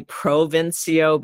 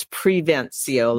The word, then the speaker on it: Prevencio: And people like to say Provincio, but it's Prevencio, like did I just, preventing.